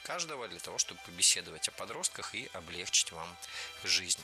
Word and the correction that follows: облегчить: Не облегчить, а облегчить. Не облегчили, а облегчили. Не облегчит, а облегчит. В глаголе «облегчить» и во всех его формах ударение ставим всегда за корнем, а корень оставляем безударным.